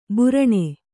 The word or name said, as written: ಬುರಣೆ